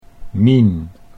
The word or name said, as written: Mien